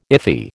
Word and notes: iffy: American English

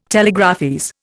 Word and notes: telegraphese: Indian

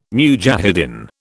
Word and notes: mujahidin: British English

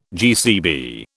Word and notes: GCB: British English

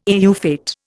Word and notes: au fait: Indian

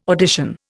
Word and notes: audition: Indian

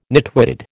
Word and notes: nitwitted: American English

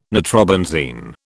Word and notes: nitrobenzene: British English

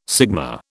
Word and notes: sigma: British English

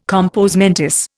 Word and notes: compos mentis: Indian

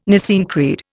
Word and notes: Nicene Creed: Indian

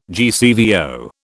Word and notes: GCVO: British English

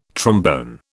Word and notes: trombone: British English

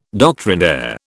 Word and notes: doctrinaire: British English

